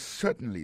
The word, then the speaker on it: certainly: Yes, certainly, sir. Yes, certainly, sir. Certainly. Yes, certainly, sir. Alors, accent tombe sou premier syllabe là. Yes, certainly, sir. Oui, certainement, monsieur. Alors, nan mon sœur qui écrit S-I-R, ça veut dire monsieur.